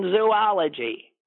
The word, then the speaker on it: zoology: Barbara writes, here's another mispronunciation that drives me crazy. She says it's zoology. There is no zoo in zoology. – It's zoology. Yeah. – Yeah. – Yeah. – One of the O's is long, and then the next one is short.